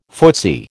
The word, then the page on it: footsie: American English